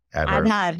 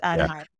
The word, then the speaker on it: Aadhaar: Is it Aadhaar? – Aadhaar, yes, it's Aadhaar.